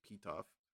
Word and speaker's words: Pitoff: No last name, just Pitoff, which is very funny to me. – So P-I-T-O-F? – P-I-T-O-F, exactly how you think it's pronounced. That's how it's pronounced.